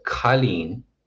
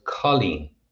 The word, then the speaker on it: Colleen: And it strikes me even at this stage that, yeah, you do meet people with that name in Ireland, but they're not really called Colleen. They're called Colleen. So it's pronounced with that kind of flatter O sound in the name anyway.